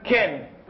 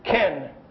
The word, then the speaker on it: chen: Así que si usted leía la Biblia en la palabra, en la lengua original, hebreo, que es el Antiguo Testamento, dijeran Ken. Así se dice la palabra, ¿verdad? Yo no sé si la estoy pronunciando bien en español, pero en inglés se dice Ken, ¿verdad? Y esta palabra es C-H-E-N.